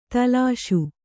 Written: ದೋಲಿಸು

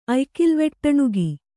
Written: ಅಯ್ಕಿಲ್ವೆಟ್ಟಣುಗಿ

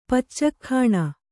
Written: ಪಚ್ಚಕ್ಖಾಣ

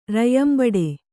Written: ರಯ್ಯಂಬಡೆ

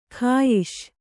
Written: ಖಾಯಿಷ್

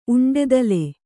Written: ಉಂಡೆದಲೆ

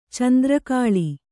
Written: ಚೆಂದ್ರಕಾಳಿ

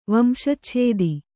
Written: ವಂಶಚ್ಛೇದಿ

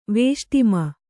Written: ವೇಷ್ಟಿಮ

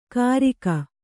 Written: ಕರಿಗ

